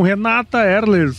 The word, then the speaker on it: Renata Erler: Estou aqui com Renata Erler, falei certo? – Falou certo.